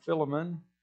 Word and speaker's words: Philemon: The book is Philemon. It is not Philemon. It is not some other way of going at it.